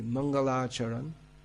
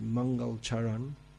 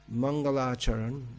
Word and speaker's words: Mangalacharan: Well, he does so at the beginning of his book, is the Mangalacharan, often mispronounced Mangalcharan. Mangalacharan, the auspicious invocation, which in although the work Chaitanya Charitamrita is a Bengali work interspersed with Sanskrit verses, basically in Bengali.